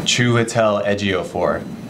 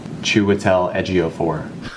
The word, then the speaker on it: Chiwetel Ejiofor: Chiwetel Ejiofor. Chiwetel Ejiofor.